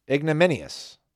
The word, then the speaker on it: ignominious: Ignominious. That's how you pronounce it, right? Yeah, deserving.